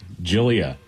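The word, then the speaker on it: Gyliah: And 19-year-old Galia Johnson. – Okay. – Or is that Jalia? It's G-Y-L-I-A-H. Help? – Jillia. – Jillia? Jillia? – Yeah. – G-Y-L-I-H.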